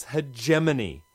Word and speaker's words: hegemony: Hegemony? – Oh, jeez. No wonder it's frayed around the edges. Words are not meant to stand up to their secondary pronunciations. It's hegemony. Hegemony.